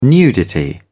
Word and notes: nudity: British English

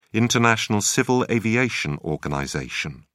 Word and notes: international civil aviation organization: British English

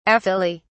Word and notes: aphylly: British English

